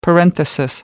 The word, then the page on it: parenthesis: American English